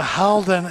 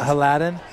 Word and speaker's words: Halden: Halden is two syllables, not three. – Halden? – Yes. – Not Haladin? – Yes.